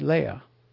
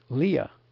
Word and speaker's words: Leah: Perhaps the wife of Jacob should be pronounced Leah instead of Leah.